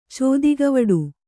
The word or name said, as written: ಚೋದಿಗವಡು